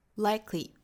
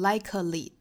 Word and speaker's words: likely: Likely，那我们也是要注意这个词，它其实是有两个音节的，不要读成 likely，哒哒哒就不对了，应该是 likely，哒哒。 They were more likely to stick，哒哒。